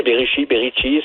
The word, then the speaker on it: Berichi: Boussad Berichi, est-ce que je prononce bien votre nom de famille? – Oui, Berichi, c'est comme vous voulez.